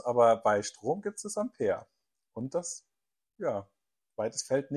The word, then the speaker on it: Ampère: Sie wurde nach einem französischen Forscher benannt und wird Ampere mit A-M-P-E-R geschrieben ausgesprochen.